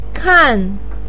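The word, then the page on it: kan: tone 4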